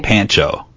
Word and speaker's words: Pancho: Now, I'm going to say it the way that it's spelled, okay? – Okay. – Pancho. – What? – P-A-N-C-H-O. – I mean, I'd say Pancho, but...